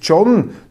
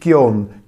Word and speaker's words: Gion: Und sein Bruder, Cherrys Bruder, ist John, nicht Gion, John Matthias Gavelti, ein Schriftsteller, Surkamp Verlag, ein brillanter Autor mit einem Flair für Stephen King und auch für Hardrock-Musik.